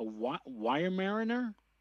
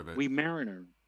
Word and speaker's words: Weimaraner: C, I hope I pronounced this correctly, a wire mariner? Weimaraner.